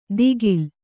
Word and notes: ದಿಗಿಲ್: Kannada

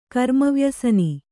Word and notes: ಕರ್ಮವ್ಯಸನಿ: kannada

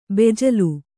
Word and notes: ಬೆಜ್ಜಿಲು: bejjilu

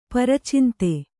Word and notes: ಪರಚಿಂತೆ: para cinte

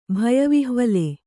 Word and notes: ಭಯವಿಹ್ವಲೆ: bhaya vihvale

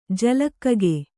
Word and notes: ಜಲಕಾಕ: jala kāka

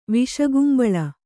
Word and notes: ವಿಷಕುಂಬಳ: viṣa kumbaḷa

